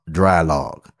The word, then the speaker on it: Draai Laag: and though the name of the brewing company is spelled D R A A I L A A G, it's pronounced Dry Log.